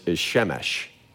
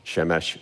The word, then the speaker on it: Shemesh: So in Hebrew, the word for sun is Shemesh, Shemesh, Shamash.